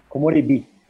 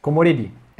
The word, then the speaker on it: komorebi: Esses dias aprendi com meu professor de japonês, a palavra komorebi. Aí você pode me dizer como que é a pronúncia correta? – Komorebi. – Komorebi.